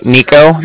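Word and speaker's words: Niko: It'll be my name, which is Nico. That's N-I-K-O.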